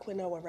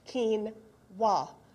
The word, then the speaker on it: quinoa: Q-U-I-N-O-A, quinoa. And Joyce very gently said to me, "You know it's not quinoa, right?" And I said, "What do you mean it's not quinoa?" She says, "it says right on the box how to pronounce it, quinoa." Oh, thank you. Great. And in that second, I thought, but afterwards I so appreciate it because I'd still be calling it quinoa.